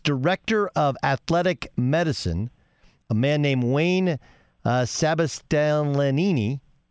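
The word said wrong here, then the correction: Sebastianelli: excuse me if I mispronounce his name. – Better you than me. – Okay. Sabastianelli. There we go. Sabastianelli.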